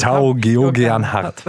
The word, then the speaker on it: Tao Geoghegan Hart: Tao Geoghegan Hart.